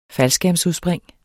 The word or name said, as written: faldskærmsudspring